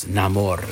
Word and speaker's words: Namor: The Submariner, which now is Namor. That's how he pronounced it. – Yeah. – Because it has that Spanish accent to it.